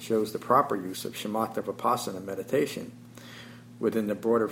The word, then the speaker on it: Shamatha: Shamatha Vipassana. Shamatha, with the emphasis on the ma, rather than just kind of a flat Shamatha.